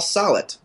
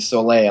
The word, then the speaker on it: Solet: My name is actually Paul Solet. It rhymes with wallet, but many people call me Solet, and I rarely correct them.